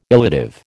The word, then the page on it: illative: American English